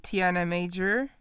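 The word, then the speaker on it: Tiana Major9: Stormzy und Tiana Major. Wird die 9 dahinter eigentlich ausgesprochen? – Nein.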